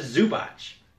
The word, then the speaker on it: Zubac: But the top story from today has to be Ivica Zubac. – Zubac, I think, is the pronunciation.